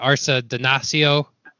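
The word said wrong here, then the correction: Arcidiacono: Yeah, Archie Diacono.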